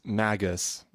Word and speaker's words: Magus: Is it with a G? – Magus. He's the, or maybe, is that, yeah, I think that's his title. – It's Magus.